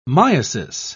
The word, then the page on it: myiasis: British English